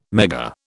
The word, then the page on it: Megger: British English